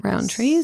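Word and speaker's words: Rowntree's: Rowntrees. Is that how you say it? – Yeah. – I think so, yeah.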